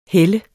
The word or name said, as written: heller